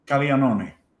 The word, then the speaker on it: Caglianone: Caglianone.